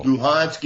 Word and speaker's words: Luhansk: I see Lugansk and I see Luhansk over and over. There's no steady use of the two terms. Which is correct, Lugansk or Luhansk? – Well, are they both acceptable? – Luhansk is in the Ukrainian pronunciation and Lugansk is the Russian pronunciation.